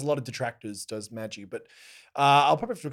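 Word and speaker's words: Magi: Depending on how you pronounce it, it depends. But I guess in English, pronunciation is Maggi, Kingdom and Labyrinth of Magic.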